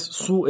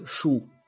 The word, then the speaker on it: Hsu: Igual te corrijo, no es Su, es Shu. – Shu se pronuncia, Shu. Perdón. – Sí. Como pasa que ella es de familia taiwanesa, entonces escriben HSU.